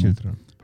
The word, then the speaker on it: Shilton: Și eram la cafeneaua, nu știu dacă pronunț bine, dar dacă spun greșit vă rog să mă iertați, Skilton, Shilton, ceva de genul. Shilton. – Shil? Shilton.